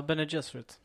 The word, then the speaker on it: Bene Gesserit: Eh, som kommer handla om det, ursäkta om jag uttalar detta fel nu, Bene Gesserit. – Ja, no, Bene Gesserit.